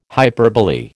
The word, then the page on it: hyperbole: American English